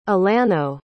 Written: alano

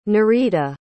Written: nereida

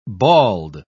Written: bald

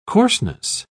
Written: coarseness

